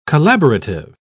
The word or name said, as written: collaborative